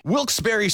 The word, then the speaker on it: Wilkes-Barre: Wilkes-Barre Scranton's 97.9X. The only way to pronounce Wilkes-Barre.